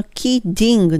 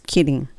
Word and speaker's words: kidding: この kidding なんですけども、この場合は本当に L の発音近くていいです。 kidding, kidding, just kidding になります。